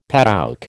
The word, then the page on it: padouk: American English